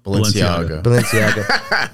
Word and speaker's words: Balenciaga: Am I saying that right? Balenciaga? – Balenciaga.